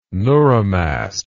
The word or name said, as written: neuromast